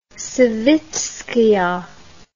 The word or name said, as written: savitskaya